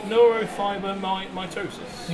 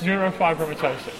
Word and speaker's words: neurofibromatosis: Is it neurofibromatosis? – Neurofibromatosis. – Neurofibromatosis. – Yeah, type 1. – Type 1. Okay, what kind of different types are there? – There are two types of neurofibromatosis, and then there's another very similar condition called schwannomatosis, that's colloquially referred to as NF3.